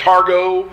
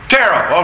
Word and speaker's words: Tarot: We've had businessmen who have made business decisions on the turn of a target card, or however you pronounce that, Targo, T-A-R-G-O-T. – Tarot, okay.